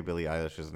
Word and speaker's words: Billie Eilish: And I always say Billie Eilish's name wrong.